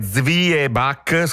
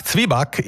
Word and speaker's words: Zwieback: Si scrive Zwieback, scritto con la V doppia e tutto il resto, ma si sente spesso dire Zibac, scritto proprio così anche. – Zibac. – Molti lo scrivono così, Zibac. Z-I-B-A con l'accento C. – Esatto, la pronuncia si semplifica, si ticinesizza, perde un po' di spigoli tedeschi e diventa Zibac, come hai detto tu.